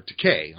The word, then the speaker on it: Takei: Well, now, to be fair, Takei was gay. Or Takei, I'm sorry, rhymes with toupee.